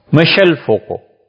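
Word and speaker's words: Michel Foucault: اسی لیے فرانس کا بہت بڑا مفکر تھا، 1926 میں پیدا ہوا، مشل فوکو، فرانسیسی میں ان کا نام ہے، ایسے ہی پڑھتے ہیں. ہم نے پوچھا تھا فرانس میں تو انہوں نے یہی تلفظ کیا،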